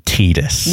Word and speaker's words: Tidus: It is officially pronounced Tidus. – Yeah, there we go. And I know that you had to swallow your pride a bit to say that. It is officially pronounced Tidus, so there's a point for you.